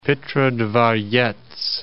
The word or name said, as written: Petrodvorets